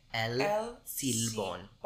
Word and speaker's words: El Silbón: El Silbón. It's S-I-L-B-O-N. Silbón.